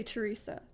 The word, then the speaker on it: Teresa: Well, Teresa, like, so I say Teresa. That's how I say my name.